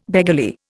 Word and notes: beggarly: Indian

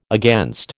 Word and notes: against: American English

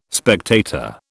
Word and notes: spectator: British English

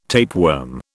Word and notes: tapeworm: British English

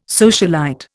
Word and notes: socialite: Indian

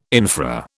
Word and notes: infra: British English

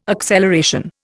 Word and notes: acceleration: Indian

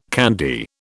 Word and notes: candy: British English

